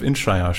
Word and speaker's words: Inisherin: Aber jetzt habe ich gesehen, dass diese Woche schon The Banshees of Inisherin startet. Und ich habe das hundertprozentig falsch ausgesprochen. Da heißt es Inisherin.